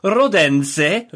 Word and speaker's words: Rodense: He also, a couple of days ago, played in a friendly at the Milanella, I think, at the training ground against Rodense, who are, I think that's how you say it, a local team. – Just like that, in fact. – Yeah, I think that's exactly right.